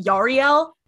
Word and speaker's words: Yarael: I, I was gonna say Yarl Poof. Yarael, guys. First of all, his name is pronounced Yarael.